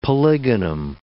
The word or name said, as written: polygonum